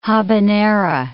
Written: habanera

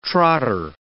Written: trotter

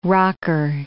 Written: rocker

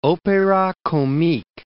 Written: opera comique

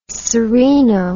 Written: sereno

